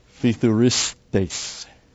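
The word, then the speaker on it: phithuristes (ψιθυριστής): You get the phi and the thu, phithuristes. And it really, if you pronounce it quickly, you get the hissing sound.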